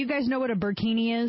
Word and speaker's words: burkini: We have the latest French police enforcing a burkini, right?